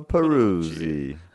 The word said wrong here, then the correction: Perugia: You are so bad. Perugia. – I think they pronounce their G's as S's.